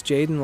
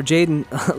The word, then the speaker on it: Jadon: My name is Jadon Lavik, and it's spelled L-A-V-I-K. Jason, or Jadon, like Jason, but with a D.